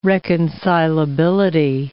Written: reconcilability

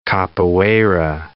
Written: capoeira

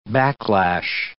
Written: backlash

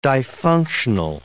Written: difunctional

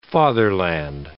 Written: fatherland

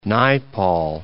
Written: Naipaul

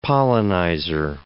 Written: pollinizer

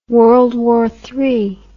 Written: World War III

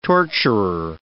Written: torturer

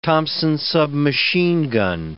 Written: Thompson submachine gun

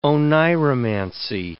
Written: oneiromancy

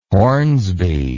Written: hornsby

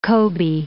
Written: Kobe

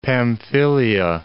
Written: Pamphylia

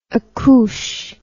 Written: accouche

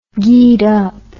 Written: geedup

